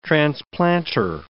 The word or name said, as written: transplanter